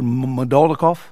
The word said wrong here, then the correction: Moldakhmedov: Moldakhmedov. – Moldakhmedov, yes.